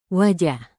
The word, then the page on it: wajah: Indonesian